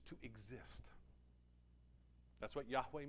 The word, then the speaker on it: Yahweh: We pronounce it as Yahweh because that's the best we can affix to a pronunciation.